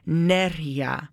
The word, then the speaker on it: Nerija: The accent is on the E, so it would be Nerija.